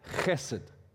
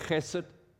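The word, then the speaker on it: chesed (חסד): Agape love is what we call the Hebrew word chesed, okay? Now, I'm not spitting on you. It's how the Jewish pronounce that H sound, chesed, okay?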